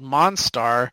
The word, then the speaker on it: Monstar: And then this villain of their past called Monstar, it's spelled M-O-N, and then it has like an icon of a star, and then it's got star at the end, Monstar.